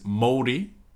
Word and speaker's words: Māori: And a Maori man, the correct pronunciation of that is Māori, I believe.